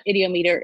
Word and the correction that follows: ideomotor: it's ideomotor effect. – Ideomotor, okay. – Motor. I knew I was saying that wrong.